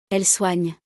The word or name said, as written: elles soignent